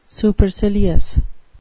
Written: supercilious

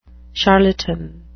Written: charlatan

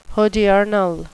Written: hodiernal

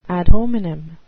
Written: ad hominem

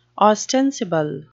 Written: ostensible

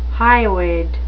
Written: hyoid

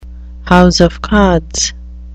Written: house of cards

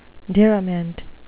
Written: diriment

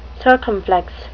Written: circumflex

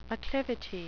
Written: acclivity

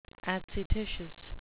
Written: adscititious

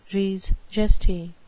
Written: res gestae

